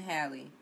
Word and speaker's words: Halle: Chloe and Halle. Like Halle Berry.